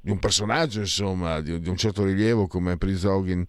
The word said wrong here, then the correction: Prigozhin: che ehm, Prigozhin che eh, si, si, pronuncia in, in, questa, in, questa, maniera.